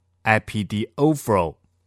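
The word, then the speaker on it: epidiophoro (ἐπιδιορθόω): 在这里，都办整齐，这个希腊文它基本上是一个复合字，它是由两个介词和一个动词所结合而成的，读作 epidiophoro。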